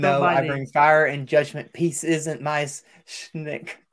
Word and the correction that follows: shtick: Shtick. – Shtick. – Pronounce the T.